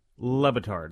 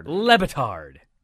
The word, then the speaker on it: Le Batard: Le Batard. – Le Batard.